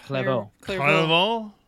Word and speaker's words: Clairvaux: Clairvaux. There's an X there. I know how to pronounce that.